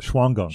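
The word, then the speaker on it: Shawangunk: And I recently saw the short-eared owl for the first time for me at the, another name that's hard to pronounce, Shuangunk? – Yeah, the Shuangunk. – Shuangunk.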